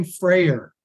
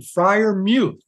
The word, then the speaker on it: Freiermuth: Stop saying Freier. – Freier. – I asked the man to come over and tell you his name. You got a personal pronunciation, Freiermuth. We both learned it's mute.